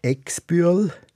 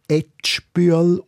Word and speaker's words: Exbühl: Dort gibt es einen Rebberg Exbühl, in der Mundart Exbühl oder auch Etschbühl ausgesprochen – die ersten Eckspüler haben eindeutig dort gewohnt und sind später auf Klingnau zugezogen.